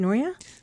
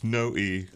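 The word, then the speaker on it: Noe: Is it Noya? – No, it's No-E. – No-E. – Yeah, my father made a point of having everyone pronounce the E somehow, and that's because the family apparently in Kentucky dropped the E.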